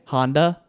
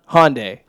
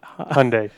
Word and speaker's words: Hyundai: Honda? – Hyundai. – Honda. – It's H-Y. – Hyundai. – Hyundai? Well, I know it's Honda. There's Hyundai.